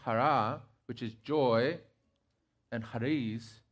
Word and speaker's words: chara: We're having the discussion, Jessica and I, because it's spelled C-H-A-R-A. But it's not, with my Spanish roots, I would say chara, but it's not. It's chara.